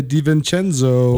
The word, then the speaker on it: DiVincenzo: Big three by DiVincenzo. Have I said it right? – You got it. Dante DiVincenzo.